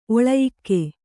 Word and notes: ಒಳಯಿಕ್ಕೆ: kannada

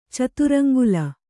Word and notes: ಚತುರಂಗುಲ: caturaŋgula

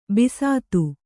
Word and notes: ಬಸ್ತಿ: Kannada

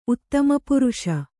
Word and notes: ಉತ್ತಮಪುರುಷ: uttamapuruṣa

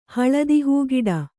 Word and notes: ಹಳದಿಹೂಗಿಡ: kannada

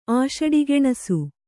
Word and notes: ಆಷಡಿಗೆಣಸು: Kannada

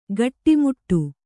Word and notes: ಗಟ್ಟಿಮುಟ್ಟು: gaṭṭimuṭṭu

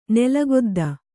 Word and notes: ನೆಲಗೊದ್ದ: Kannada

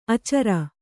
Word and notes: ಆಚಾರಿ: kannada

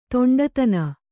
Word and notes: ದಿಂಡುತನ: diṇḍutana

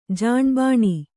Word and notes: ಜಾಣ್ಬಾಣಿ: kannada

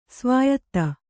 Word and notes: ಸ್ವಾಯತ: svāyata

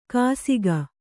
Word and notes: ಕಾಸಿಗ: kāsiga

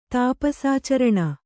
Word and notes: ತಾಪಸಾಚರಣ: tāpasācaraṇa

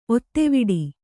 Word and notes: ಒತ್ತೆವಿಡಿ: kannada

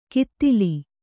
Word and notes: ಕಿತ್ತಿಲಿ: kittili

